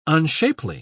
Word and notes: unshapely: British English

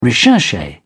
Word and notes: recherche: British English

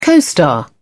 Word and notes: co-star: British English

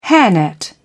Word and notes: hairnet: British English

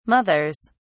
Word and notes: mothers: British English

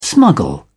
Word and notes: smuggle: British English

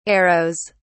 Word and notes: arrows: British English

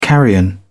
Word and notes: carrion: British English

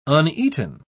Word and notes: uneaten: British English

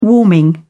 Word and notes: warming: British English

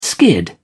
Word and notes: skid: British English

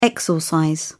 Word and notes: exorcise: British English

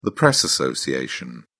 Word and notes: press association: British English

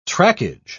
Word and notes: trackage: British English

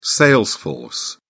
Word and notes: sales force: British English

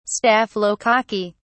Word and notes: staphylococci: British English

